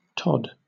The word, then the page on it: tod: British English